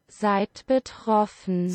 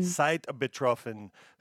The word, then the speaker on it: Seid betroffen: To me, better pronunciation again. – Seid betroffen. – Seid betroffen.